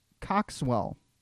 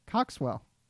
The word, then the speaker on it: Coxwell: So the poem of the moment this week is by Howard White Coxwell, and the poem. – Coxwell. I'm pretty sure you're not pronouncing that right. – C O X W E L L. – Say it now. – Okay. Coxwell.